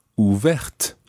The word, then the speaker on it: ouverte: So, ouvert ends in a T, which is silent. Ouverte ends in the TE, making the T pronounced.